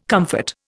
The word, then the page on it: comfort: Indian